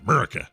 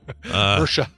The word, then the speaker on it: Murcia: Last month, police in Murcia, I believe is how you say it. Although here we would pronounce that Murica. – Murica. – Murica. – Murcia.